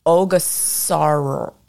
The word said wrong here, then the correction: Ogasawara: No, Ogasawara Islands. Okay, I'm so sorry.